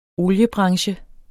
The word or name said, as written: oliebranche